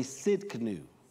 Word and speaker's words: Tsidkenu: The T is silent. Say Tsidkenu.